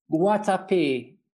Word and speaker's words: Guatapé: Guatapé, Guatapé.